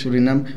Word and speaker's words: Surinam: ¿Es de Surinam? ¿O Surinam? ¿Cómo se dice? – Surinam.